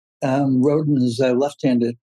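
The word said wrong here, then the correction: Rodón: Rodon, you got to use the pronunciation.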